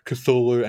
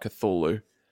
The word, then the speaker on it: Cthulhu: I fluctuate between Cthulhu and Cthulhu. – Okay. I've heard nothing but Cthulhu. – Yeah. I think that's the most accepted because that's sort of how you would pronounce it in English. – Sure. – You know, C-T-H-U-L-H-U.